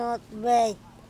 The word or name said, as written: otbec